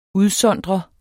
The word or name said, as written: udsondre